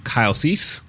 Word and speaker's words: Kyle Cease: So sitting across from me is a gentleman by the name of Kyle Cease.